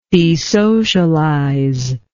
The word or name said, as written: desocialize